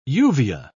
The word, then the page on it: uvea: British English